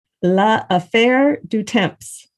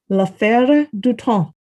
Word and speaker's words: L'Affaire du Temps: The challenge was anyone unfamiliar with the French language would have seen it and most likely would have read and pronounced it as La Affaire du Temps. But its French pronunciation is more along the lines of L'Affaire du Temps.